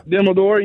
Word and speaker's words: Dumbledore: Dumbledore, yeah.